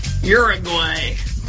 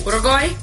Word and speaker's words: Uruguay: Uruguay. Say it like American. – Yeah. Can you say it as a non-American? – Well, it doesn't start with a your sound. – Oh. – Uruguay? – Uruguay?